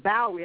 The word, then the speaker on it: Bowie: Bowie. – I don't know how to say it. – Okay. – B-O-W-I-E.